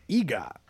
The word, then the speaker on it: Iga: Okay, as it turns out, a small Japanese city named Iga. I'm assuming I'm pronouncing that right.